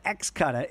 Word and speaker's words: Xcutta: It's called Xcuta. It's spelled X-C-U-T-T-A.